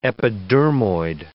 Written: epidermoid

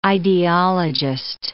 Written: ideologist

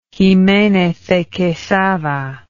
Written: Jimenez de Quesada

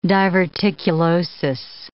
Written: diverticulosis

